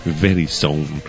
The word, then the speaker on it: Verizon: Que tenemos la pronunciación inglesa, está tan mala, pero bueno, la pronunciación exacta sería Verizon.